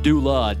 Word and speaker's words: Dula: His real name was Tom Doula, D-U-L-A, but they just kind of pronounced it Dooley.